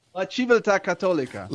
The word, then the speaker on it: La Civiltà Cattolica: La Civiltà Cattolica.